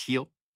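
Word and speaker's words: Gil: That's G-I-L. It's Heel. – Mm-hmm. – That's how it's said.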